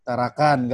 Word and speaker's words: Tarakan: selama di rumah deh di di ditarakan ditara ditaraan ya sekarang tarakan nggak pakai an bukan pak bukan tarakan pembacanya bukan kayak paraan ya pembacanya Gak